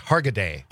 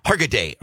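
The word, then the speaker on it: Hargitay: I can never pronounce her name. – I think it's Hargaday. – Hargaday.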